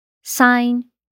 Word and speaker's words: sign: Seguindo com a nossa lista, a letra G não é pronunciada quando acontece a combinação GN no final de uma sílaba. Foreign, estrangeiro, sign, assinar, and align, alinhar.